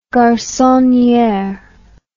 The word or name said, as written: garconniere